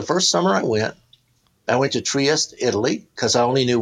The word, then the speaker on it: Trieste: Yeah, Trieste.